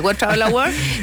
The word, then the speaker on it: World Travel Awards: Y hoy día Chile está postulando a ganarse un premio a nivel mundial, que es el World Travel Awards. ¿Lo dije bien? Sí. – Sí, World Travel Awards.